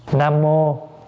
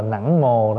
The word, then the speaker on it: Nam mô: Nam mô, có chỗ đọc là nẵng mồ đó, chứ thật sự là chữ nẵng mồ là nam mô đó. Chữ nam mô đã phiên âm là nẵng mồ.